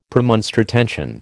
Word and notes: Premonstratensian: American English

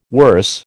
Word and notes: worse: American English

